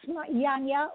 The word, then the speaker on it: Janya: And yes, I will clarify my name for sure, because pronounce Janya with a Y at the beginning, not a J.